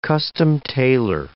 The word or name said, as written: custom-tailor